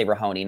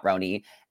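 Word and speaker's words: RHONY: I say Rahoni, not Roni. – And oh my God, why? I love it. Rahoni. – The H, Rahoni. – Silent H. – No, silent H.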